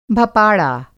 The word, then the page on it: भपाड़ा: Hindi